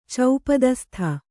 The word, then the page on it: ಚೌಪದಸ್ಥ: Kannada